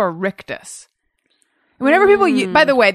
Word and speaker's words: rictus: Spell that. – R-I-C-T-U-S. – Rictus. – I think that's right. – I believe it's rictus, and it's like a like giant Joker-like smile that's plastered on your face that I have in all my photos.